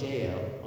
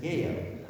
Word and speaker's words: Yael: Nor did I know that it's not Jael until she pronounced it Yael at the time, which makes sense because it's a Hebrew name.